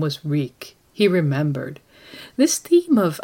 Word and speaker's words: Reek: My name is Reek. It rhymes with bleak.